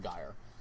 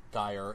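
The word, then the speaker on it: Geier: I think he pronounces it Geyer. It's G-E-I-E-R. I'm assuming that's pronounced Geyer.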